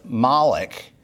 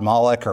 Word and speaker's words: Moloch: Some people have the theory that the pagans who sacrificed their children to Moloch, the false god Moloch or Molech, depending on how you want to pronounce it, were imitating Abraham's sacrifice of Isaac.